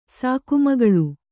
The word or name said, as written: ಸಾಕುಮಗಳು